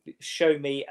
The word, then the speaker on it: Sowunmi: It's spelt Sowonmi, Sowunmi, but it's pronounced Show Me.